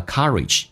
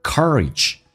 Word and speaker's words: courage: The courage, 英式发音叫做 courage, 美式发音重度卷舌微笑的形式 R, courage, in your, 嘴巴凸出来收圆整个舌身往回卷 your heart, 嘴巴空间更大一点依然是卷舌音 in your heart.